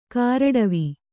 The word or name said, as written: ಕಾರಡವಿ